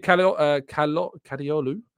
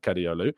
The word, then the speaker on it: Kadıoğlu: Ferdi Kadıoğlu. I don't think you pronounced the G.